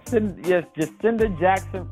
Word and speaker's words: Jacinda Jackson: Jacinda Jackson from San Antonio, Texas.